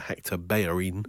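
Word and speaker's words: Bellerín: You may have seen this on your social media accounts when Jeremy Corbyn had a chat with the Arsenal defender Hector Bellerin.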